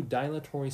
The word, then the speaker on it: dilatory: And the sentence is, Kristen took a few dilatory steps toward the dentist's office before running away. Ah, we understand that. So let's hear the... – Dilatory. – Okay, so that's how you pronounce it.